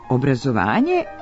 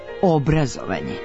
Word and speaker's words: obrazovanje: I obrazovanje i obrazovanje. – Prema jednotomnom rečniku srpskog jezika Matice srpske, oba oblika, Obrazovanje sa kratkouzlaznim akcentom na prvom slogu i obrazovanje sa dugouzlaznim akcentom na pretposljednjem slogu jesu pravilna.